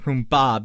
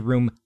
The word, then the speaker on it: Roomba: I pronounce it Roomba because I always want to say Roomva. – Roomba. – Because room vacuum. Because that's what makes sense. But it's not a room vacuum. It's a Roomba.